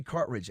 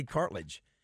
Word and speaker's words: cartridge: I can't say cartridge. I want to say cartilage. I can't say cartridge. I feel like I'm saying it wrong. Is that right? Is it cartridge? – Cartridge.